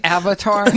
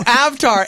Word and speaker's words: Avtar: Did you just say Avatar? – No, Avtar. A-V-T-A-R. That's his name.